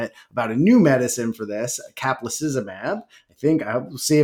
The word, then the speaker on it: caplacizumab: So what caplacizumab is, and you pronounced it correctly, is a monoclonal antibody fragment that binds the von Willebrand factor and prevents its interaction with platelets.